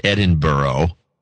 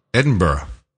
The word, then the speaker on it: Edinburgh: That came in from Edinburgh, Scotland, properly pronounced Edinburgh. So we had a big discussion, the family discussion, about this. How does E-D-I-N-B-U-R-G-H get to be pronounced Edinburgh? – Edinburgh. – It's pronounced, but it's Edinburgh is what they say.